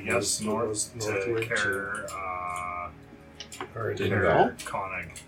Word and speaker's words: Konig: Conig. – Conig. K-O-N-I-G.